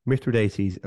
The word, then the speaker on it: Mithridates: The Romans were fond of fighting wars against Mithridates, and I checked the pronunciation, Mithridates of Pontus.